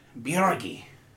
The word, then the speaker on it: Biorge: B-I-O-R-G-E. Birgi. It's Norwegian.